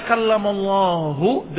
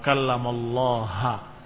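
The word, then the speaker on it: kallamallahu (كَلَّمَ اللَّهُ): Tadinya kallamallahu dengan dhammah, mereka ganti wa kallamallaha Musa taklima. Kalian tahu tentang nahwu. Kalau fa'il maka dia akan marfu'. Kalau maf'ul maka dia akan mansub. Maka kalau bacanya dengan kalimat wa kallamallahu dengan dhammah pada lafzul jalalah, maka maknanya Allah Subhanahu wa taala fa'il, Allah lah yang berbicara, Allah mengajak bicara Musa.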